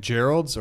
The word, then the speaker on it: Gerald: And Gerald's, or is it Gerald's? – You're right. The correct pronunciation is Gerald.